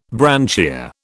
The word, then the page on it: branchia: British English